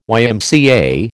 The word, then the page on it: YMCA: American English